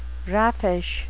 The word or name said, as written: raffish